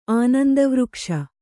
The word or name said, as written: ಆನಂದವೃಕ್ಷ